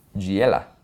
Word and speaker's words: Giella: Giella. – Like Giada, almost.